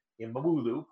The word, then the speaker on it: İmamoğlu: And it is not inconceivable to see that someday Ekrem İmamoğlu, pardon me, the G is silent in Turkish, I know that much, that he could very well, because he's now a national figure.